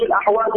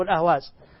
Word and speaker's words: الأحواز: أنت تريد الأحواز بالحاء أم بالهاء؟ – بالحاء. – بالحاء. – الأحواز الأحواز بالحاء. – بالهاء أم بالحاء؟ – بالحاء. – بالحاء. طيب، هو هكذا نحن ننطقها الأحواز، ربما أخطأوا وكتبوا الأهواز، مكتوب الأحواز.